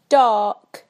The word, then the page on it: dark: British English